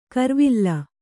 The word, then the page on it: ಕರ್ವಿಲ್ಲ: karvilla